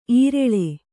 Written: ಈರಳ್ಳೆ

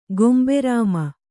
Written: ಗೊಂಬೆರಾಮ

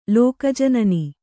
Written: ಲೋಕಜನನಿ

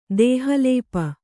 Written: ದೇಹಲೇಪ

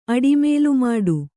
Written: ಅಡಿಮೇಲುಮಾಡು

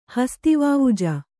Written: ಹಸ್ತಿವಾವುಜ

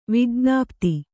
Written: ವಿಜ್ಞಪ್ತಿ

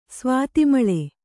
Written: ಸ್ವಾತಿಮಳೆ

